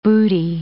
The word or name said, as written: booty